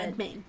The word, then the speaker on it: Redmayne: I really like Eddie Redmayne. However you say his name. He was really good. – Redmayne, yeah.